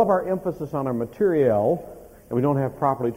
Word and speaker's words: materiel: And most people, whenever I use that word, they always correct my pronunciation. I'm not talking about material, okay? I'm talking about materiel.